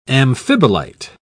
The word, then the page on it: amphibolite: British English